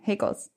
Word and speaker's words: Haeckels: C-K-E-L-S. How do you think it's pronounced? – Heckles. – Oh, okay. Heckles.